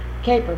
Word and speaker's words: Koeper: Caper, we call them. – Caper? – Uh-huh. K-O-E-P-E-R. – Huh. Well, this Cooper is an interesting name. How would you pronounce that? C-O-O-P-E-R.